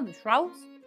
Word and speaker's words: The Shrouds: The Shrouds.